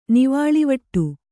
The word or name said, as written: ನೀವಳಿವಟ್ಟ